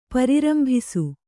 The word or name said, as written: ಪ್ರಾರಂಭಿಸು